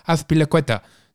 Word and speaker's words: Azpilicueta: It's nice that you've gone for the pronunciations as well. That's become important on the podcast. Azpilicueta. Nice.